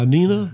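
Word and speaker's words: Nena: By Nina.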